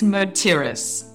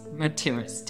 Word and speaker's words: Mertiris: And it's Mertiris. Mertiris. Debbie Mertiris.